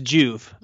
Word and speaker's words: Joovv: Yeah, it's pronounced Joovv. It's kind of a play on rejuvenate.